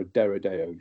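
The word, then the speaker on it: Deredeo: Um, I then got a Deradio dreadnought or a Deradeo dreadnought to pronounce it.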